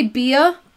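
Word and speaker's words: Beah: I'm going to say Bea, because it's spelled like Leah with a B.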